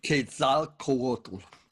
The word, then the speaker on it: Quetzalcoatl: And in your book, and also, you know, sprinkled here and there in your conversation so far today, you've made reference to Quetzal. I never know if I get it pronounced. – Quetzalcoatl. – Thank you.